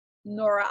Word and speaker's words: Nora: Purchase my book, which is linked in the bio on my Instagram, Nora Oz, OZ_queen. Awesome. And Nora is spelled NORA. No H, please. Don't do that. – No H. – No silent H.